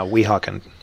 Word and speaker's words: Weehawken: Yeah, Weehawken.